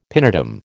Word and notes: pinetum: American English

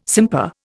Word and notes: simper: Indian

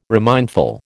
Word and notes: remindful: American English

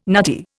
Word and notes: nutty: Indian

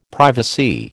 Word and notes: privacy: American English

